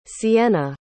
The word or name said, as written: cienna